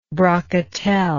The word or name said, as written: brocatel